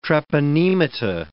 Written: treponemata

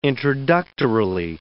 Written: introductorily

